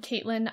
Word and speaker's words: Caitlin: I'm Caitlin IR Foster, and that's CAITLIN.